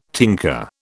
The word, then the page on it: tinker: British English